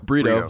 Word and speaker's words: Brito: Brito or Brito? – Brito. – So I said it right. – It's like Brito without the burr.